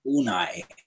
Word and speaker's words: Unai: Can anyone tell me how you actually pronounce his first name? – Unai, I believe. – Unai? – Unai.